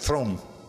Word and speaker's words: Theron: In Afrikaans, it's pronounced Throm.